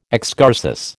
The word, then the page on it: excursus: American English